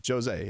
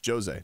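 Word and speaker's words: José: And Texter says, just FYI, J is pronounced like an H. Not with him. He's Jose. It's Brazilian. It's different. It's different. He's convinced everybody it's Jose, not Jose. Just saying.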